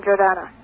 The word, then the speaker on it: Jordana: Hi, I'm Jordana. – How do you spell that? – J-O-R-D-A-N-A. – J-O-R-C-D. – A-N-A. Jordan with an A.